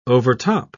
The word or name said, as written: overtop